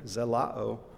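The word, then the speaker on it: zelao (ζηλόω): Those all translate the Greek word zelao, and I pronounce it because you can hear our English word zeal in there, zelao.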